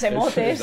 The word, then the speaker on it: emotes: Es que mis emotes, esatto. – Perdona, pero en español se dice emotes, ¿vale? Así que no te burles de mi pronunciación.